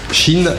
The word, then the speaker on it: Si2N: Chine. Ça s'écrit S-I-2-N et ça se prononce Chine.